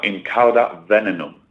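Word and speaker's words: In Cauda Venenum: And I asked him, how do you say it then? And he goes, well, incauda venenum.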